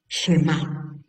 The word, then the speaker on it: Shemá: Tenemos una palabra muy significativa que es Shemá. Shemá, Shema, y acento en la A, Shemá, la S, como si fuera una Che, ¿no? Una C y una H, Shemá, Shemá.